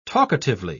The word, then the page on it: talkatively: British English